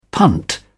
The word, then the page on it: punt: British English